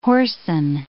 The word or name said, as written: hoarsen